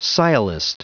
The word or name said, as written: sciolist